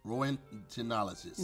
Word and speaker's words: Roentgenologist: Roentgenologist. – No. Roentgenologist or roentgenologist. The O is silent.